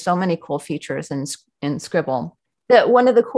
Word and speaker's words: Scrible: They call it Scribble, but it's spelled Scribble. So it's spelled S-C-R-I-B-L-E.